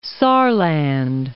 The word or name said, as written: Saarland